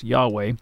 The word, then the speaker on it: Yahweh: So the Hebrew name for God, sometimes pronounced in English as Yahweh, if you were going to transliterate the Hebrew letters, it would be YHWH.